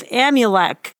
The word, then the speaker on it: Amulek: The words of Alma and also the words of Amulek. Am I saying that right? – Yeah, that's his toy boy.